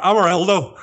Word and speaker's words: Amarildo: And yeah, the player who came in for him was a guy called Amarildo. Have I pronounced that right? You'd probably be able to tell me. – I'm from Northern Ireland. Amarildo. – That sounds right to me, man.